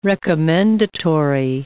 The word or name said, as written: recommendatory